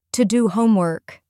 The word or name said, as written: to do homework